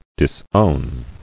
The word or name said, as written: Disowning